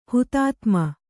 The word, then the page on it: ಹುತಾತ್ಮ: hutātma